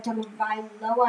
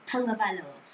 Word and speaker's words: Tagovailoa: So I do not think personally that you should get to a Tagovailoa. I think I said Tagovailoa. Yes, I think I said that right.